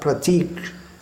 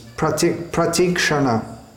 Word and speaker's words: pratikshana: Pratikshanasva Dana, but it's not Pratik. It's pratikshana, pratikshana svadana, not pratikshana svadana.